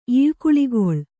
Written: ಈಳ್ಕುಳಿಗೊಳ್

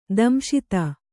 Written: ದಂಶಿತ

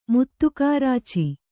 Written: ಮುತ್ತುಕಾರಾಚಿ

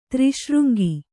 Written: ತ್ರಿಶೃಂಗಿ

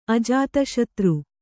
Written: ಅಜಾತಶತ್ರು

